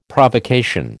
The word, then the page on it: Provocation: American English